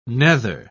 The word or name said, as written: nether